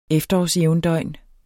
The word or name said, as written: efterårsjævndøgn